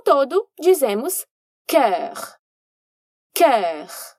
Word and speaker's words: cœur: C-O-E-U-R. É o exemplo típico de uma palavra que não pronuncia como se lê. E olha só! Aqui, a gente vai ter o mesmo som que encontramos na palavra olho, œil, mas agora com um som consonantal antecipado. Vamos lá, então. Cœur tem apenas uma sílaba, mas vamos pelos sons. O primeiro é da consoante C, que aqui vai ficar k, que também encontramos na palavra colis, d'accord?